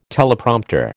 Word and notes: Teleprompter: American English

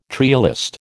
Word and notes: trialist: American English

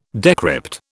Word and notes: decrypt: British English

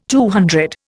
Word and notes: CC: Indian

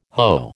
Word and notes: Ho: American English

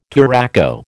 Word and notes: turaco: American English

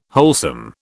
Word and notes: wholesome: British English

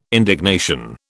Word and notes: indignation: British English

